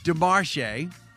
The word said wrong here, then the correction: DeMarche: No, it's DeMarkey, because it's the C is the H is silent.